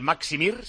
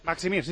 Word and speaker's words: Maksimir: En el Maksimir, se pronuncia así, el estadio del Dinamo de Zagreb. – Maksimir, sí señor.